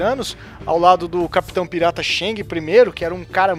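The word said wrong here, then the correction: Zheng: A pronúncia é meio difícil do chinês, né? A pronúncia certa é Zeng.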